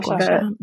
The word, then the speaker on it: gua sha: Is it gua sha? – Yes. – Like gua sha.